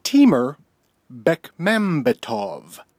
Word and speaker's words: Timur Bekmambetov: Timur Bekmambetov.